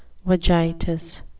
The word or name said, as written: vagitus